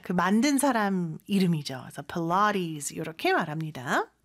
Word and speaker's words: Pilates: Pilates. 필라테스인데 발음이 참 독특하죠, 그죠? 필라테스라고 하면은 사실 원어민들은 못 알아들을 수 있을 것 같아요. – Yeah. – Pilates. 이렇게 발음을 합니다.